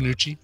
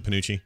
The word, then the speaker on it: Panucci: In the episode Jurassic Bark, they find Fry's dog Seymour in a museum exhibit in Panunzi's Pizza, where Fry used to work. Am I saying that right? I probably remember. – Panucci. – Is it Panucci? Yeah, Panucci's Pizza.